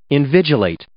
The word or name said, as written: invigilate